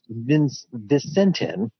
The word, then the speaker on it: Vicentin: Well, earlier this year, actually right around the first of the year, one of the largest soybean processing companies, I believe it's pronounced Vicentin, V-I-C-E-N-T-I-N, declared bankruptcy.